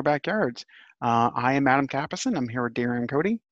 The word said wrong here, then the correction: Dorian Cody: And I'm sorry, Dorian Cody.